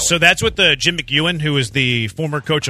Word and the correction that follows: McElwain: That's pronounced wrong. – How do you pronounce it? – Isn't it McElwain? – Maybe.